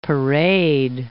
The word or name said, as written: parade